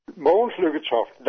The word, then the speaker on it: Mogens Lykketoft: And sir, I think your name is something is pronounced like Mogens Lykketoft. Please pronounce your name for me. – Mogens Lykketoft, that's my name.